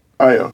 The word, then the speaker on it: Ayo: The actress who plays, is it pronounced Ayo or Ayo? I think it's Ayo, right? – Ayo.